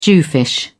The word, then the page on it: jewfish: British English